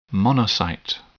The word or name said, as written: monocyte